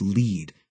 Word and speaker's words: Lead: My cousin lived 40 miles north in the town of Lead, which rhymes with speed.